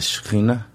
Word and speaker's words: Shekinah: And I believe, I bet you'll know how to say it, Shekinah? – The Shekinah.